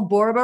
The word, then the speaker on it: Borba: Borba rhymes with Zorba, so you'll always remember me the rest of your life.